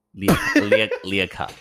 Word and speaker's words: Leacock: Leacock. How do you say that? L-E-A cock. I mean, like, it's Leacock. – L-E-A cock.